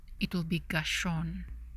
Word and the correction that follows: Gershom: Am I saying that right? Gershon? Gershon? Or is it Gershom? Let me scroll down. Oh, it's an M. Gershom. I'm saying Gershon with an N. Gershom. Gershom. Gershom.